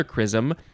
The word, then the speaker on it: chrisom: So is this this is pronounced the same way as the other chrisom, but it is spelled C H R I S O M.